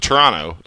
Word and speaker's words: Toronto: But, like, no one in this city calls it – it's same thing like with Toronto. Like, Toronto, you don't pronounce the T at the end unless you're from out of Toronto.